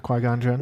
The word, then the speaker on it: Qui-Gon Jinn: Yeah, Qui-Gon Jinn.